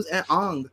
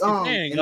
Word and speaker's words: Aang: I'm not like, ooh, who's Aang?